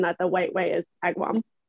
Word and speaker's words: Agawam: Agawam. – I think it's Agawam. I don't think you pronounced the middle A.